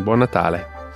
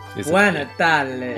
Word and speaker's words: Buon Natale: Buon Natale. – Buon Natale.